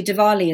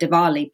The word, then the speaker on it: Diwali: So actually Diwali is a good one. So yes, in English we call it Diwali, but actually we in Gujarati would say Diwali.